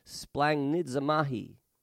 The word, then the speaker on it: splanchnizomai: Compassion in the original Greek is spelled S P L A N C H N I Z O M A I. It's splanchnisnamai. Can you say that? Actually, it's pronounced "splangnidzamahi."